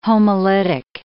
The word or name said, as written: homolytic